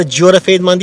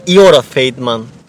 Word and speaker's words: Giora Feidman: E, bu arada Giora Feidman değil, Iora Feidman. Feidman diye okunuyormuş. Çünkü G'ler okunmazmış. Iora ya da Yora denirmiş.